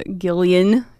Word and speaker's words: Gillian: With Gillian.